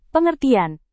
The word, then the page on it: pengertian: indonesian